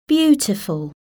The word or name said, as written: beautiful